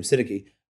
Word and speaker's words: Syntyche: And it's Euodia and Syntyche in Philippians, or Syntyche, how do you say her name? Syntyche.